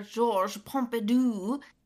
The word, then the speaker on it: Georges Pompidou: And walking around, the way that he said, I will butcher this. I took French for years, but my French is awful. But essentially, he's walking into the center, Georges Pompidou, and the way he says I think you said that right. – Thank you. Right? Yeah. That's right, right? – I think that's right.